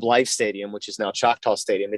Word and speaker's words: Choctaw: At, I don't want to butcher this, is that Choctaw Stadium? – Choctaw, yeah.